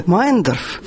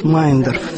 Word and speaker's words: Мейендорф: Это мемуары Марии Федоровны Мейендорф. Фамилию они именно так произносят, Мейендорф. Все пытаются произнести так, как пишется, но на самом деле семья вся сама произносит Мейендорф.